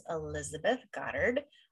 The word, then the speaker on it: Elizabeth Goddard: Today we have a very suspicious author who writes all kinds of suspense, and her name is Elizabeth Goddard.